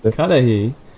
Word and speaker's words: Cudahy: transacted at his bar in the city of Cudahy. I don't know how you pronounce it. – Cudahy.